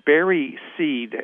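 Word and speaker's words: Barreseid: I would suggest that there is huge amount of evidence that it's, and I don't know how to pronounce it, it's Barry Seed, it's B-A-R-R-E-S-E-I-D, from Chicago area.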